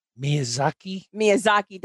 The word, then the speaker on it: Miyazaki: Miyazaki.